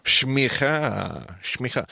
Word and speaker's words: s'micha: Shmicha. – The way I spell that, by the way, again, transliteration, and I always spell it differently than everybody. But for me, S'MICHA. Shmicha. S'MICHA.